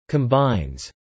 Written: combines

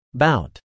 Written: bout

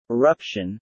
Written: eruption

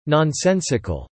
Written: nonsensical

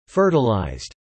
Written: fertilized

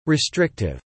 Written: restrictive

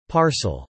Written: parcel

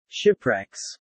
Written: shipwrecks

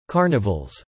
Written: carnivals

